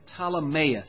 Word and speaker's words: Ptolemais: Well, we say it after the Ptolemies, the Ptolemy rulers. We say it Ptolemais. You could say Ptolemais, I suppose, but we don't make the P so emphasized here. Ptolemais greeted the brethren and stayed with them one day.